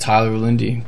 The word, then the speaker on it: Tyler Linde: S-A-L-D-A-N-A. That's my last name. Underscore 313. That's Detroit's area code. – Mine's just Tyler Lindy. T-Y-L-E-R-L-I-N-D-E.